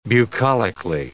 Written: bucolically

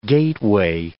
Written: gateway

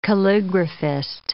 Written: calligraphist

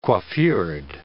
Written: coiffured

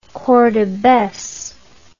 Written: cordobes

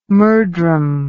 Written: murdrum

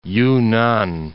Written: Yunnan

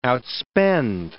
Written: outspend

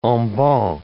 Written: en banc

